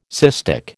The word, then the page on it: cystic: American English